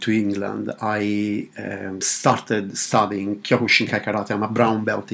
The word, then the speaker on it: Kyokushinkai: Kyokushinkai.